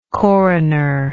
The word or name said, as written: coronership